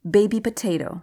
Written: baby potatoe

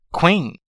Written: queen

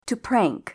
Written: to prank

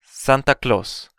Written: Santa Claus

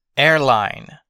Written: airline

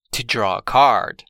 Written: to draw a card

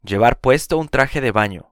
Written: llevar puesto un traje de baño

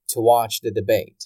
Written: to watch the debate

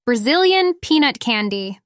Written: Brazilian peanut candy

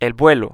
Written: el vuelo

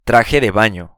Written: traje de baño

